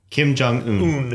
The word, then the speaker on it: Kim Jong-un: How do you say his name? – Kim Jong-un. – Un. – Kim Jong-un. – Un. – Okay. – Like Uno, except without the O.